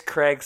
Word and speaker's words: Craig: It's Craig spelled normally, C-R-A-I-G. Last name, Horlbeck, H-O-R-L-B as in boy, E-C-K.